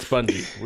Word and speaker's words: Bungie: What a childish way to pronounce that, Rutledge. I don't understand. It's Bungie. We all know that.